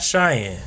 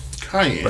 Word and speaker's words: Cayenne: Cayenne. – Not Cheyenne. – Cayenne. – But like Cayenne. Like, yeah, I know. Is it spelled like Cayenne? – Yes.